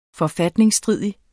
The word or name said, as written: forfatningsstridig